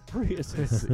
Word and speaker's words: Prius: You can buy like a Prius, like four Priuses. – You said it wrong. It's Prius.